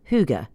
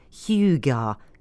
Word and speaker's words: hygge: Hygge, hygge, hygge, and hygge are among the approximations offered in the at least 9 books on hygge published this autumn. The Sun, helpfully, suggests it should rhyme with cougar.